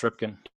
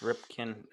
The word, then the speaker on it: Ripken: I know Cal Rippin. – Yeah, no, that's Ripken. – Yeah, it's Ripken.